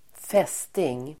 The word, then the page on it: fästing: ²f'es:ting